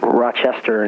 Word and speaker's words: Rochester: You say it like you're from Rochester. Because everybody else says Rochester, and you say Rochester.